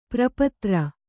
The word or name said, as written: ಪಾರಪತ್ರ